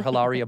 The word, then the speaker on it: Hilaria: It is going to be named Hilaria after Hilaria Baldwin. Of course, you don't pronounce the H. It's Hilaria.